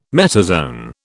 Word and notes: metazoan: British English

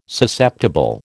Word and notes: Susceptible: American English

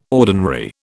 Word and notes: ordinary: British English

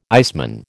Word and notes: iceman: American English

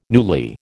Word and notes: newly: American English

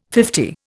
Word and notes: fifty: Indian

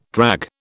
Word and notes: drag: British English

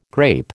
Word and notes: grape: American English

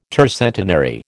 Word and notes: tercentenary: American English

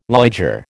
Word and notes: liger: American English

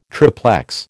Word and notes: triplex: American English

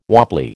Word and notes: wobbly: American English

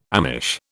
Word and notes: Amish: British English

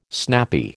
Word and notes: snappy: American English

